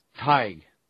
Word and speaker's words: Tadhg: But here is the proper pronunciation of T-A-D-H-G. – Taig, Irish name meaning a poet or a philosopher. – Hyde. – Taig. – Taig. – Like T-Y-G.